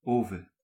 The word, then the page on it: furnace: /ˈfɝnɪs/; /ˈfɜːnɪs/